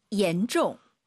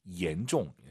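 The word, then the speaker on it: 严重: 얀종. – 혹시 지금 마음속으로 얀종 하신 분 계십니까? 중국어의 얀이란 발음은 없어요. Y를 I로 바꾸세요. I-A-N, E-N입니다. 실제로 한글 이로 시작하는 발음이에요. 얀이 아니라 옌, 옌종.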